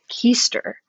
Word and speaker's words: Kester: It's Kester, yeah. – This is also a podcast where I learned that I've been mispronouncing my friends' names. – So we're confused. – The name itself, though, because it's spelled dumb. – And we have another friend whose last name is spelled the same way, but she pronounces it Keister. – Well, she's obviously wrong.